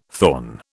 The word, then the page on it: thorn: British English